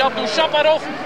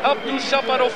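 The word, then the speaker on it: Abdoujaparov: Weilen Mark van Lombeek die de klemtoon anders legt. Die zegt Abdou Shaparov zullen wij dat ook maar zeggen.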